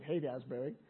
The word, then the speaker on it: Haight-Ashbury: that height Asbury, but they pronounce it Haight Asbury.